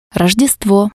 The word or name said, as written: Rojdestvo